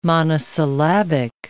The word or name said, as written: monosyllabic